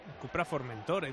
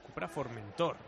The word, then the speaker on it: Cupra Formentor: Cupra Formentor, he dicho. Me está diciendo Bercero que lo he dicho mal otra vez. – ¿Qué has dicho? ¿En vez de? – Es Cupra Formentor.